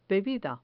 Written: bebida

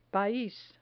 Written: pais

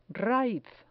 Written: raiz

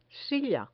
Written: silla

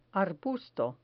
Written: arbusto

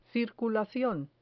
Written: circulacion

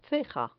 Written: ceja

